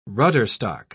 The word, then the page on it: rudderstock: British English